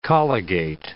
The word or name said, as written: colligate